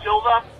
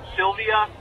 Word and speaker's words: Silva: It's Anderson the Spider Silva, not Sylvia or Sylvius, whatever Aaron said, which was minuscule.